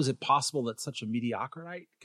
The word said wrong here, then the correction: mediocrity: mediocrity yeah whatever mediocrity I guess